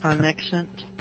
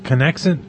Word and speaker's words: Conexant: Conexant. – Conexant. – Conexant. – C-O-N-E-X-A-N-T.